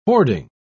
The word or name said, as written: hoarding